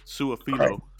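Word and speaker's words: Su'a-Filo: this guy Xavier Suafio. Su, Su, how do you say his name? Suafilo. Suafilo. Suafilo.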